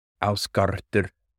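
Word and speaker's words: Ásgarðr: One I really have to learn that's going to be really difficult for me is the proper pronunciation for Asgard is actually Ausgardr, Ausgardr, and I messed that up, I know that, but I'm doing my best.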